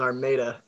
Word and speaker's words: Armada: The pronunciation of my town is Armada, actually.